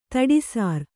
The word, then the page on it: ದಿಟ್ಟಿಸರ: Kannada